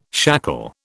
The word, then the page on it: shackle: British English